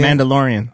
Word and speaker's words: Mandalorian: I'm just excited about Mandatorian. What? How do you say Mandalorian.